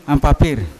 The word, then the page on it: ampapir: Yawa; Unat